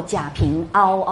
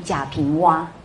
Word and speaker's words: 贾平凹: 请注意哦，这个字啊，真的，一般是呃念蛙，所以大陆有一位非常有名的小说家，应该不不要念作贾平凹哦，应该要念贾平蛙才对。